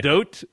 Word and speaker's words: Gadot: He has texted me, and he says the O in Godot is pronounced like the letter O. Nobody has put more research into this than me. I'll wager my entire existence on this, having talked to multiple Israelis about it. I can also link you to multiple videos where she advises this pronunciation. – Okay. – Gadot, like doting dad. So it's pronounced Gadot, apparently.